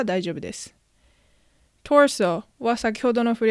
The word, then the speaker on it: torso: 日本のカタカナではトルソーと表現されることが多いようですが発音はト s o ー、 o r ソーになります。カタカナの「ル」にしてしまうと L の発音が入ってしまうのでしっかりと R の発音に変えて torso